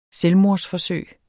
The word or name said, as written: selvmordsforsøg